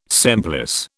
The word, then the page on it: semplice: British English